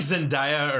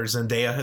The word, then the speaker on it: Zendaya: I like Zendaya, or Zendaya is how you say, I always say it wrong.